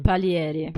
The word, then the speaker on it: Palieri: Palieri.